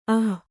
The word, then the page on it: ಅಃ: ah